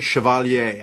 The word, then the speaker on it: Chevalier: And I pronounce it the French way, Chevalier. I don't know if it's Chevalier, like the way, you know, I've been thinking about Chevrolet. That's the way I'm going with it.